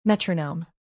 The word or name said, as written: metronome